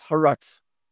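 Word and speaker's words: Charaz: It's spelled with a C, but it's pronounced Haratz.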